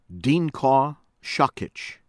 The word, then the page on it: Dinko Sakic: American English; from Croatia